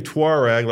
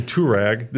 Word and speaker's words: Touareg: You say Tuareg like the British. I say Touareg. This is an African tribe.